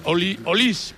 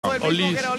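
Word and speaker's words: Olise: Olis, porque en francés todas las finales que no van acentuadas pronuncian Olis.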